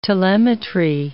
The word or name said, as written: telemetry